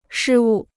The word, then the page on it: 失误: shī wù